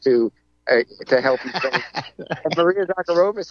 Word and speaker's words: Zakharova: Is that how you say it, Zakharova? The Kremlin. – Maria Zakharova. Yeah.